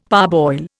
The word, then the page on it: parboil: Indian